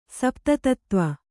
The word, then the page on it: ಸಪ್ತತತ್ತ್ವ: sapta tattva